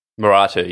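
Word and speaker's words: Merantau: I think it's pronounced Meratu. – Meratu, yeah.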